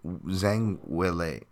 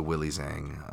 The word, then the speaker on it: Zhang Weili: Zhang Weili. Uh, sorry, I was I can't I can't stop thinking of um, just the Willie Zhang uh, pronunciation.